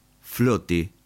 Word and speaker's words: flote: Float, flote, F-L-O-T-E, es, dice Anderson, la pronunciación alemana de la palabra flauta.